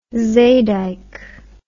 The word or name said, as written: zeedijk